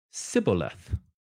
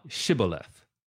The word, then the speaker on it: Shibboleth: Then said they unto him, say now Shibboleth. And he said, Sibboleth. So they say it with a sh. He said it with a s. Say now Shibboleth.